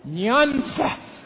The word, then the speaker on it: Nyanza: Southwest Nanza? N-Y-A-N-Z. Nyanza.